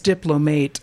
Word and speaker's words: diplomate: No, actually, despite its spelling, it's pronounced diplomate.